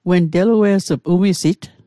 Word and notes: umisit: Mi'gmaq

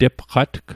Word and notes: tepqatg: Mi'gmaq

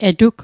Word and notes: etug: Mi'gmaq

